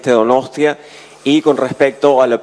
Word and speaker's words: Donostia: En primer lugar, debo decir cuán humilde y cuán sorprendido también a su vez y agradecido por recibir el se dice Donostia, sí, se dice Donostia Wood. – No, it is right, okay. – Hasta el intérprete me está diciendo que lo pronuncio bien.